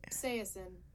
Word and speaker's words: Saison: Did you hear how you say it? – Say it then. – Oh. – What? – I definitely said it wrong. – Saison? – Saison. – Interesting. – We love the silent three letters.